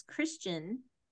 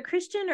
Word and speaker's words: Kristian: I believe it's Christian, but it's spelled K R I S T I A N. Would you pronounce that Christian? – Yeah.